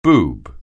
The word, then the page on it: boob: anglais